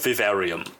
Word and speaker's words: Vivarium: Also wenn du es Englisch aussprechen willst, dann äh, Vivarium. Aber wenn du es auf Deutsch lassen willst, und ich meine, das, das Wort gibt es auch auf Deutsch, ne? Vivarium.